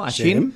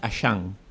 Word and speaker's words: Ashin: Por cierto, aquí he leído tal como se pronunciaría en castellano, Ashin, ¿sí? Pero la pronunciación en original, en coreano, es Ashan.